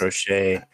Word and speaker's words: Crochet: Garrett Crochet, I think that's how you say his name for the White Sox. – Crochet.